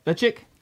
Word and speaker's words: Ledaig: It is called Lichick. Lechik? – Lechik. – It's spelled L-E-D-A-I-G. – So, of course, you would pronounce that Lechik.